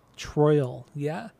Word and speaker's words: Troyal: He was born Troyal Garth Brooks. So his first name is spelled T-R-O-Y-A-L. – Troyal? – Troyal, yeah.